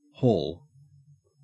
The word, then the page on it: haul: Australian English